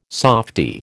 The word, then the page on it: softie: American English